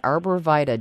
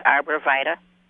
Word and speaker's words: Arborvitae: That is how the natives pronounce it, and I always accepted it until I heard, I think her name was Carol Cowan, pronounce it Arborvitae. Obviously, she studied Latin and pronounced it correctly.